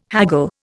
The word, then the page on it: haggle: Indian